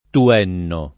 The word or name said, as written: Tuenno